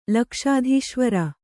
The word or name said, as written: ಲಕ್ಷಾಧೀಶ್ವರ